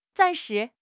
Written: zan4 shi2

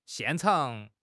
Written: 现场

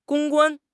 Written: 公关

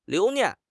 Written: 留念

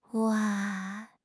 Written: 哇

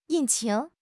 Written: 引擎

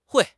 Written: hui4

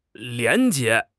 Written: 廉洁

